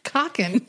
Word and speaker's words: Coquin: Tell me if that says coquin. I'm trying to pronounce it French. – Oh, coquin. – Coquin. – Cockin. No, that's clearly cockin.